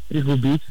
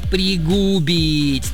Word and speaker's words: пригубить: Ну, я думаю, пригубить. – Нет. Данное слово образовано от существительного «губа», представляете? А не от глагола «бить», как вы подумали. Поэтому правильно говорить «пригубить», то есть прикоснуться губами.